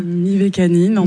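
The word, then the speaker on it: Mivekannin: Mivacana ou Mivacanim? – Mivacanine, en tout cas c'est comme ça Mivacanine. Que moi je le prononce.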